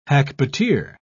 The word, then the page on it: hackbuteer: British English